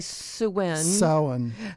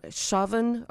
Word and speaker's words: Samhain: In reading about it today, both of those pronunciations are said to be incorrect by people who know Gaelic. And the one that I was found was, and that's what I don't, Shavin or it's S-H-A-V-I-N. That was the most authoritative pronunciation.